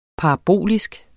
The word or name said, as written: parabolsk